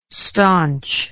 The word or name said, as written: stanch